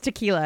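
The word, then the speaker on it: Tikealla: I thought it was Tequila. It's Tequila. I heard him say it. He said Tequila. – I've never seen it spelled like that. – Yeah, it's T-I-K-E-A-L-L-A, but they call her Tiki, which I think is a really cute nickname.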